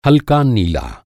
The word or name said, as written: नीला